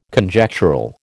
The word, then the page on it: Conjectural: American English